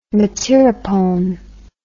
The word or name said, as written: metyrapone